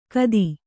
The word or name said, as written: ಖತಿ